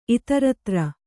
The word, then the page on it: ಇತರೇತರ: kannada